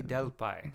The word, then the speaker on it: Delpy: Julie Delpy.